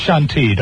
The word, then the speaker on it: Chantilly: The action gets underway at 9 o'clock next Wednesday night at Chantilly Bowl America. – This is a team that's very near and dear to Lisa's heart, right? – Bunch of Lisa's friends. – Ah. It's pronounced Chantilly.